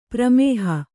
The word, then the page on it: ಪ್ರಮೇಹ: pramēha